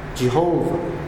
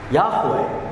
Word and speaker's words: Yahweh: Because in the 19th century, scholarship in those days say, we think the correct pronunciation of the name of God is Jehovah. Now, 100 years afterward, modern day biblical scholarship says, we don't think so. We think the most likely pronunciation of the covenant name of God is Yahweh. Yahweh.